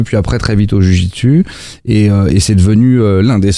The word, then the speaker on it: jiu-jitsu: Vous avez même été prof de jiu-jitsu. – C'est ça, vous l'avez bien prononcé.